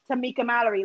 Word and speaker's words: Tamika Mallory: like Bernie and Tamika Mallory.